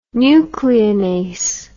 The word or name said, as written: nucleinase